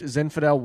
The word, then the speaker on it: Zinfandel: That is a left Zinfandel wine Zinfandel.